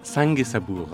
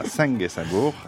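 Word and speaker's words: Syngué sabour: Vous prononcez vous-même le titre, ce sera mieux que moi. – Sangue Sabour. – Sangue, voilà, je ne savais pas s'il fallait. Voilà, Sangue Sabour.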